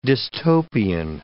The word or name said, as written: dystopian